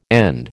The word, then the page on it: end: American English